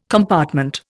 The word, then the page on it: compartment: Indian